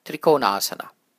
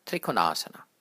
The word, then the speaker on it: trikonasana: The O and the first A are both long vowels. Let me say it now with the long O and the long A and see if you can hear it. Trikonasana, trikonasana. Typically, people shorten the O from which you get trikonasana, trikonasana. But the right way of saying it is trikonasana.